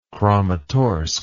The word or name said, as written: kramatorsk